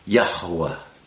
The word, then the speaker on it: Yahuwah: Hey is a breath, ha, yah, and then wa, hey, wah, yah, hua, and you'll hear me pronouncing that hey in the center with a little more emphasis, Yahuwah, Yahuwah. It's two syllables really,